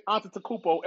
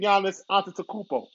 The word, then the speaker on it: Antetokounmpo: See, since I know how to say his last name, it's the rap. Now I'm going to say Antetokounmpo every time. Before I used to say Giannis. Now I'm saying Giannis Antetokounmpo. Oh, that's how you say his name. Yes, that's how you say it.